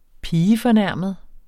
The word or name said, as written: pigefornærmet